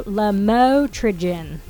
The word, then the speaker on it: lamotrigine: No, lamotrigine. That's how you say it.